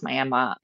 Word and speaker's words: Miami: I mean, growing up, I'm actually from one town over, Miami, which spelled like Miami for readers, but it's pronounced Miami.